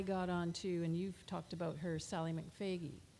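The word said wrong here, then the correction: McFague: And McFaig. – I always say that wrong.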